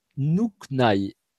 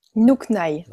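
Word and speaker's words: Nhûkny: Alors, ça s'écrit, je n'ai pas de tableau, mais ça s'écrit N-H-U, accent circonflexe, K-N-Y. Et ça, le, comme le U avait un accent circonflexe, se prononce OU, et le Y dans ce mot-là se prononce I, ça fait Nuknai.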